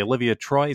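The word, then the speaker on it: Olivia Troye: But it's then high level anonymous staffers like Miles Taylor and now yesterday Olivia Troy. Is that how we say her name? – You got it.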